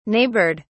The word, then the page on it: neighboured: British English